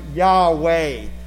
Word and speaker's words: Yahweh: YHWH. It's pronounced Yahweh.